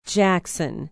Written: Jackson

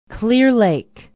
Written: clear lake

